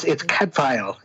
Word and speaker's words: Cadfael: So, brother, am I saying this right, Cadfael? – Yeah, it's Cadfael. It's Cadfael, Welsh, okay.